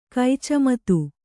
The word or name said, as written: ಕೈಚಮತು